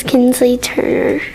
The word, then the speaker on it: Kensley Turner: Its name is Kensley Turner.